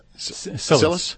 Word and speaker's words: Cilice: So the spiky chain worn around the thigh, how do you pronounce that? – Cilice? That's right.